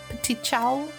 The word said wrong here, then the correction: petit chou: Petit chou. – Petit chou. Pronounced it incorrectly that night.